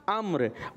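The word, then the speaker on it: अम्र (عمرو): तो इसी तरीके से ये वाव जो है ये साइलेंट है और इसको पढ़ेंगे हम अम्र।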